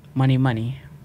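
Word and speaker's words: Mony Mony: How did you say that again? – Money, Money. – Does it spell like money? – Well, money.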